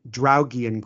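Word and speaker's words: Draugiem: And I really liked your pronunciation of the Latvian word, Draugian Group. I know that for an American, the R's are quite difficult, and I loved it.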